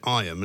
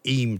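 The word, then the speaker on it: Eyam: But the first thing I learned was how to pronounce it, because some people pronounce it Iam and some people pronounce it Eam. So I'm told Eam is correct. Is that right? Locals pronounce it Eam. – Oh, Eam, OK. So the Y is kind of silent. – Pardon? – The Y is a sort of silent Y.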